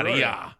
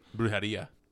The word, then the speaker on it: Brujeria: On the way here, I was listening to fucking, what the fuck's that, however you pronounce it, Brujeria. – Yeah, Brujeria.